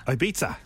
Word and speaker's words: Ibiza: The next word is Ibiza. – Ibiza. – Have you got it right? Everyone says Ibiza. And you are correct. Ibiza. – Ibiza. – Ibiza. – Ibiza. So it's a Spanish pronunciation.